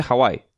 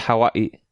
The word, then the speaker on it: Hawái: O sea, no se dice Hawái. Se dice Hawái. – Oh, Hawaii. cuidado. Entonces ese... Es porque tiene la oquina entre las dos I's.